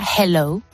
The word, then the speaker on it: hello: Ha dado un truco y es que para poder pronunciar esa H de forma correcta, pues tenemos que hacer como cuando echamos el aliento de la boca para calentar nuestras manos. Pues escucha. – Entonces sería hello.